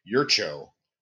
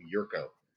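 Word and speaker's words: Jurčo: I was watching a game the other day where the announcer, like, because Yurko was on, and the announcer was, and I'll say correctly, calling him Yurcho, And every time I heard it, it was like, that is technically his name. You are actually pronouncing it right. But it is still bad for me to hear you not call him Yurko.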